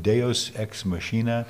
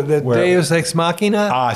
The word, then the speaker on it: Deus ex machina: This is the kind of the opposite, and I probably butcher if this is Latin, the concept of Deus ex machina. – Oh, Deus ex machina? – Told you I'd butcher it.